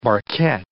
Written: barquette

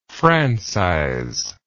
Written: francize

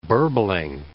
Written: burbling

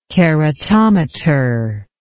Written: keratometer

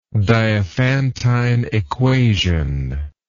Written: diophantine equation

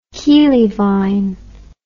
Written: keelivine

